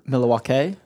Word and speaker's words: Milwaukee: Milwaukee? Is that what we pronounce it? – That's correct, yes.